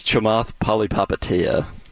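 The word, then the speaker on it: Chamath Palihapitiya: You're close. It's Shamath Palihapitiya. Yeah.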